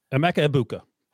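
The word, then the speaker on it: Emeka Egbuka: Emika, Ibuka, yeah. Ibuka? Is that the word? Uh, Igbuka, correct? – Emeka Ibuka. – Emeka Ibuka. – I think it's how he pronounces it.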